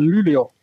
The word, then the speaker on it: Luleå: Ich würde sagen, Luleå, auch sehr gut ausgesprochen.